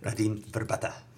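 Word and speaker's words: Radim Vrbata: Radim Verbata. That's how it's pronounced.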